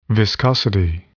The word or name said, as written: viscosity